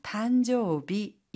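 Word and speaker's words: 誕生日: 단조비 이때 일이 비로 발음이 되죠.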